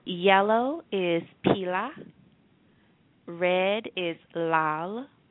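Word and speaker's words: lal (लाल): I-L-A. And it's pronounced pila. The second color is going to be red, and red is pronounced lal. I'll say it again, lal, and that is spelled L-A-L-A. So it's spelled like lala, but it's pronounced lal.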